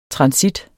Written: transit

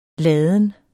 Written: ladens

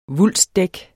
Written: vulstdæk

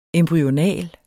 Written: embryonal

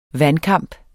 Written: vandkamp

